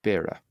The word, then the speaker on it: Beara: Beara was B-A-R-A, Beara.